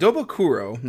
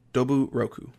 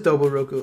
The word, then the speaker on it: Doburoku: Oh, Yeah, Doba, I said it wrong when I was like, this is his name. I was like, Dobakuro. No, Coach Doburoku. – Oh, it is Doburoku.